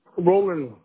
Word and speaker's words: Rowland: Do you spell it like the way my friend spells it, D-E-R-E-K? – Yes, ma'am. – Okay, and what's your last name, Derek? – Roland. – R-O-L-A-N-D? – R-O-W. – R-O-W-L-A-N-D. R-O-W-L-A-N-D, right?